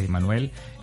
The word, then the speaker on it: Jemanuel: @ Yemanuel Oficial, mi nombre se escribe con J, Jemanuel, es Yemanuel, en todas las redes sociales y mis plataformas digitales de la misma manera.